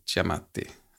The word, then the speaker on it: Giamatti: Men där, jag vet inte, jag, jag tror, ingången i den, jag tror att det var jag tror att det var Paul Giamatti, uttalas det så? Ja.